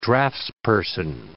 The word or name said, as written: draftsperson